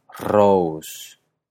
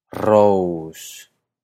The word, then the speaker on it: Rós: Entonces, Rós, que se escribe R o con acento S, se dice Rós. Rós. Con Sigur, que se escribe S-I-G-U-R,